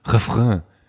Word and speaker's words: Refrain: Genauso wie Refrain, wusste ich auch nie, Refrain. Ja, wie sagt man denn dazu? – Refrain. – Also habe ich auch lange falsch ausgesprochen.